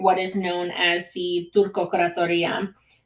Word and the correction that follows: Turkokratia: Sorry, I always like to add syllables to things. Turkokratia.